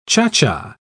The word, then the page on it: cha-cha: British English